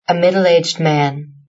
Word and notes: a middleaged man: British English

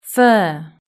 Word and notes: fur: british-english

